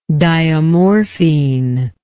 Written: diamorphine

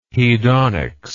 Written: hedonics

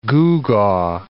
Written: gewgaw

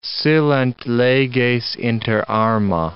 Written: silent leges inter arma

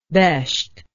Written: Besht

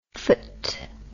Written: phpht

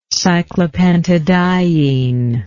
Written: cyclopentadiene

